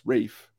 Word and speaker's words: Ralph: think you Rel, Rafe, yes, Rafe. I, I, don't know for years I called him Ralph. – Well, because his name is spelled with a friggin' L in it.